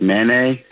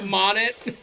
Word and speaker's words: Monet: That's how you pronounce it? I thought it was like Manet. – Monet.